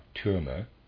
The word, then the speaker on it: Türmer: Readers, aside, Türmer is spelled T-U-R-M-E-R, Türmer, end of readers aside.